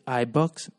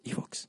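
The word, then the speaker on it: Ivoox: Porque mucha gente, hey, no, es, es una empresa española y no se dice iBox, se dice eBooks o eBox.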